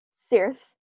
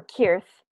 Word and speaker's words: Cirth: Kuzdul has a 50-letter runic alphabet called Cirth or Kirth, depending on how you want to pronounce a C-I-R-T-H. – I do Cirth, but that's just me.